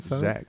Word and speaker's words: Xaq: Zach.